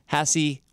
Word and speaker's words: Haase: This is an interview with McKenna Haase, or as she says, Haase, which rhymes with sassy.